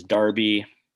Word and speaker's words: Derby: But then it made me think, like, we call it the Kentucky Derby, right? – Right. – So we don't call it the Kentucky Derby. So clearly that's just British English pronunciation. But I think actually you're going to, I think we're all going to experience the Football Neophytes Derby. When you're in town in December, we're going to go and watch Wolves Man U. So there it is. The correct pronunciation is Derby, and it's essentially just a local rivalry.